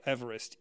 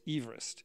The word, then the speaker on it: Everest: So apparently his name, so this is Neil's fact of the moment, he pronounced his name, the surveyor who noted it, not Everest, Everest. Everest. Because you see, he was British. It sounds much like Everest.